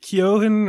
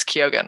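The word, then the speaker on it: Keoghan: I think I've always called him Kyogen, but then people are like, isn't it Kyohan or Kyohan? Or I fully was saying it wrong until I went and saw this movie with my Irish friend, and she's like, yeah, it's Kyogen.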